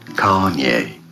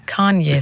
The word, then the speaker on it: Kanye: Kanye, finde ich, sagst du super. – Ich glaube, die Betonung ist auf der ersten Silbe.